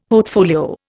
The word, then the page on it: portfolio: Indian